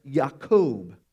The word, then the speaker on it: Yaakov: In the Hebrew, Jacob's name is pronounced Yaakov.